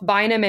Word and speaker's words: Vinum: Well, it's the Latin word for wine. So technically, you know, it's Vinum. – A dead language. So I've heard both Vinum and Vinum, actually.